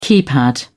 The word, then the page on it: keypad: British English